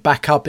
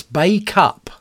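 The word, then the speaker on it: Bacup: Barry D's got my pronunciation wrong. I said back up. It's Bay Cup.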